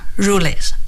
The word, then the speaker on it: Jules Verne: In these books, Jules Verne, that's the French way of it, Jules.